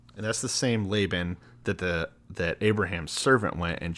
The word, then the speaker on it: Laban: Labian? – Laban.